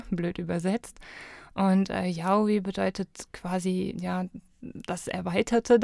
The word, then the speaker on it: Yaoi: Ja, richtig, Yaoi.